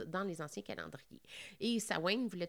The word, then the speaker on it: Samhain: Et ce sont donc les origines aussi des fêtes dites de la Samhain, qu'on écrit S-A-M-H-I-N, mais il faut vraiment prononcer Samhain,